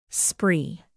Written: spree

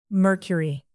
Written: mercury

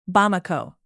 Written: Bamako

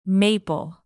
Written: maple

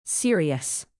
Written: serious

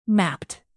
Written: mapped

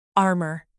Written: armour

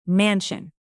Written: mansion

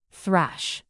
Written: thrash

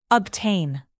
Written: obtain

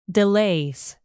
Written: delays

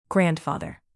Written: grandfather

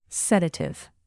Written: sedative

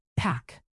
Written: pack

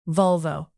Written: volvo

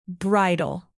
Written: bridle

